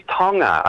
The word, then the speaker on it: Tonga: It is Tonga, a soft G.